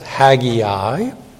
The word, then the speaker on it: Haggai: Haggai, or as some pronounce it, Haggai. It's like the AI, it's like Sinai, Sinai, Haggai, Haggai. Actually, it'd be a little different in the Hebrew, but we speak English.